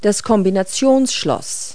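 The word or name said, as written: combination lock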